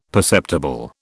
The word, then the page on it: perceptible: British English